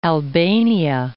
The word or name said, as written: Albania